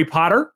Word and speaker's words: Potter: So my name is Jerry Potter. Yes, it rhymes with the boy wizard,